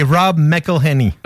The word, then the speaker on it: Rob McElhenney: c'est Rob McElhenney.